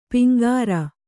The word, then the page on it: ಪೆಂಗುರು: peŋguru